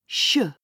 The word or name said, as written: sh